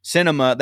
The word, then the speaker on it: Sinema: Sinema.